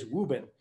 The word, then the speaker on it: Woburn: And the way we pronounce Woburn is Woobin.